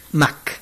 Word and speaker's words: Mac: Doctor, y el día de hoy nos va a presentar el tema, si no me equivoco, los rituales agrarios en la fiesta de Ma'ak. – Mac. – Mac. Sí. Tengo problemas fuertes con la pronunciación del maya.